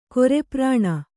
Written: ಕೊರೆಪ್ರಾಣ

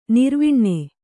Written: ನೆರೆವನೆ

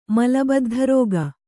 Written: ಮಲಬದ್ಧರೋಗ

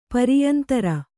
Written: ಪರಿಯಂತರ

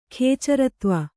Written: ಖೇಚರತ್ವ

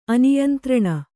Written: ಅನಿಯಂತ್ರಣ

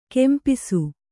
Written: ಕಂಪಾಸು

